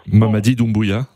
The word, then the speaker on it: Doumbouya: le colonel Doubia. Je ne sais pas si je le prononce très mal. – Mamadi Doumbouya.